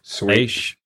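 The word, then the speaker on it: Leisz: When Matthew Sweet met, how do you say his last name? – Leish. – Leish. When he met Greg Leish, not Lake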